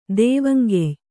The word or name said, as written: ದೇವಂಗೆಯ್